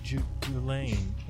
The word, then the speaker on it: Ghislaine: But yeah, especially because they, you know, I see people arguing about it's actually pronounced Ghislaine. Like, shut the hell up. – Yes. – Get the S out.